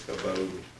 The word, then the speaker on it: kavod: It's the word, I was thinking you would pronounce it, kavod. It's KAW/BODE.